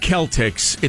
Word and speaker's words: Celtics: It's pronounced Celtics. It's a culture, not a costume.